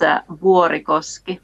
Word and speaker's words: Vuorikoski: If you want to pronounce it like Finnish do, it's Vuorikoski.